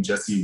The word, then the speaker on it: Jesse: Just look up my name, Jesse Wuji. That's J-E-S-S-E. Last name is I-W-U-J-I.